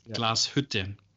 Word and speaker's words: Glashütte: It's Glashütte. Glashütte Original is how we would pronounce it in Germany. But you're getting really close.